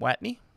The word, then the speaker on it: Watney: Watney.